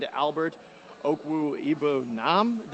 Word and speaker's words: Okwuegbunam: Mizzou then responded with a long pass to tight end Albert Okwuegbunam. Did I say that right, Andy? – We're very close. Very close enough.